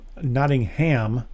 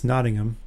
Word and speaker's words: Nottingham: which is the British pronunciation. If it was the American pronunciation, it would be Nottingham, the Sheriff of Nottingham. But in England, it's Nottingham.